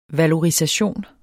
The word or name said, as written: valorisation